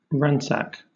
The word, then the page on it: ransack: British English